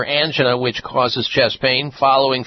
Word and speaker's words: angina: That would be angina. Some people pronounce it angina.